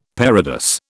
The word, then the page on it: parados: British English